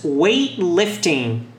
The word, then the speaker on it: weightlifting: Então, repita: soccer, basketball, basketball, volleyball, volleyball, tennis, tennis, running, que é corrida, running, weightlifting, levantamento de peso. Veja que eu não pronuncio esse T aqui, é apenas weightlifting.